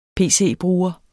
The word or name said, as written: pc-bruger